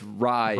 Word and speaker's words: Raya: So it's Raya.